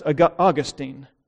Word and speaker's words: Augustine: One of the most famous testimonies in church history is the conversion of the church father Augustine, sometimes pronounced Augustine.